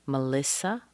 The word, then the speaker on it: Melissa: Melissa, a woman's name. Melissa. That's a typical pattern of a three-syllable name. Da-da-da. Schwa, primary stress, schwa.